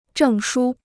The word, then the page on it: 证书: zhèng shū